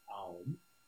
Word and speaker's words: Aum: It's actually not pronounced Om, it's pronounced Aum. Aum, that's what it is.